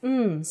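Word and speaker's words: Mm (唔): The first character is in a very low tone, fourth tone. It sounds a bit like when someone is thinking about a plan and they say, hmm. Notice that there is no vowel in the syllable, so you have to really stress the mm sound.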